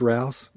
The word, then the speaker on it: Rouse: It's Rouse, like Mouse, except with an R.